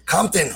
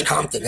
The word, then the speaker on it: Compton: Sí, es, es Compton, es Compton. No Compton, es Compton.